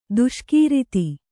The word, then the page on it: ದೇಸೆಗಾರ್ತಿ: dēsegārti